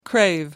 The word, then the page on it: crave: American English